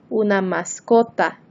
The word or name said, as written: una mascota